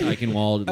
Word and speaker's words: Eichenwald: So is it Eichenwald? – German isn't a language. It's Eichenwald. It looks how it's spelled.